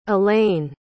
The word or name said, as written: elayne